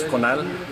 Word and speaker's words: Conal: Sí, mi nombre, primer nombre es Conal, se escribe C-O-N-A-L, apellido es Hueten, W-H-E-T-T-E-N.